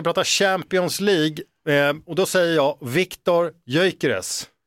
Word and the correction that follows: Gyökeres: Och då säger du fel. Han heter Gyökeres, inte Gyökeres.